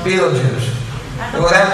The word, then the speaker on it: Betelgeuse: By the way, it's spelled Beltegeuse, but pronounced Betelgeuse, all right?